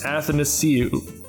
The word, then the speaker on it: Athanasiou: Nope. Dang it, I knew I would get that wrong. it's a complicated name. Let me try it again because I have it written phonetically. Nice. Uh, and Andreas Athanasiou. I had it, didn't I? Yeah, yeah.